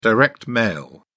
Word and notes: direct mail: British English

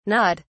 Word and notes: gnawed: British English

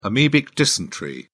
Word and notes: amebic dysentery: British English

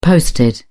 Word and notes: posted: British English

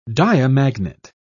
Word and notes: diamagnet: British English

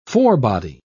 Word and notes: forebody: British English

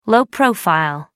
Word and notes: low profile: American English

